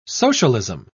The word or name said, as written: socialism